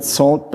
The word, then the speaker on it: NaCl: It has been developed by Daniel Bernstein, and he called it SALT, but it's spelled N-A-C-L, so I'm sorry for the play on words, but if you see N-A-C-L, it's SALT.